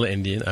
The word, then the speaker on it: endian: He posted an article here talking about memory addressing, and, you know, it gives a definition of big Endian and little Endian. I don't know if I'm saying that right or wrong. – No, that's right, yep.